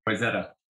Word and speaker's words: Wayzata: Wayzata.